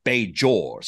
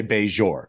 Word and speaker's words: Bajor: For instance, Patrick Stewart says Bajor. So he just is just a J. And other people I've heard say Bajor. What is it? Which one is the proper pronunciation? – I would say Bajor.